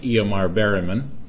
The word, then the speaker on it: Ingmar Bergman: Some people call him Ingmar Bergman. I think the proper pronunciation is something like Ingmar Bergman.